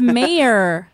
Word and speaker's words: Mayer: It's my last name is Mayer. – It's Mayer. – Oh, like John Mayer.